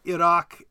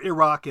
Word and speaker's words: Iraq: We're not speaking Arabic or Farsi, and I think Iraq and Iran, or Iraq and Iran, are fine. I mean, technically, in those languages, you sort of roll your r a little bit too least with Arabic.